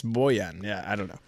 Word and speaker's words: Bojan: He calls him Bojan. I like that. I'm pretty sure Boyan has its pronounced Boyan. Like, that's what we were told when he first got here.